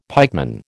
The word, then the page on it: pikeman: American English